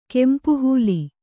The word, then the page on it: ಕೆಂಪುಹೂಲಿ: kempu hūli